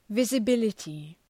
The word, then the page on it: visibility: ,vızə’bılətı